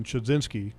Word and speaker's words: Chudzinski: And they have a coordinator that last year, I guess, kind of was transitioned to that role in Chudzinski.